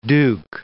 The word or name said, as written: duke